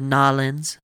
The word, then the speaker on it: New Orleans: Well, I did take that trip down to Nolens, that's how the locals say it, to meet with a company client.